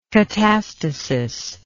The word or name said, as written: catastasis